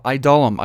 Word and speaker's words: Adullam: I pronounced it wrong earlier this week. I'm going to correct that today. The cave of Adullam. I love that because the word dull is in there.